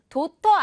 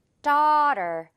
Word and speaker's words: daughter: 도터 아니고 우리 미국 영어 기준으로는 어 발음, daughter가 되겠어요. daughter, 더 부분을 길게 끌어주면서 어 발음 유지, 맛있는 떡 발음 유지.